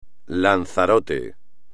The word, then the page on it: Lanzarote: espagnol